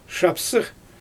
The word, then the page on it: ʃapsɯx: Xyzyl